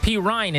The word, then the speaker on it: Perine: Oh, so am I supposed to call him Perine? – Perine is his name. There's no Ryan there.